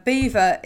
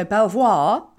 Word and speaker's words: Belvoir: And for anybody that's actually listening, Beaver is spelt B-E-A-V-E-R. Belvoir. So Belvoir.